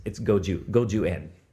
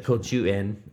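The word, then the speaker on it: goju en (五十円): And it's goju en. – That's the Japanese pronunciation. – That's the correct. If somebody had said like "kochu en" or "gochu en," right.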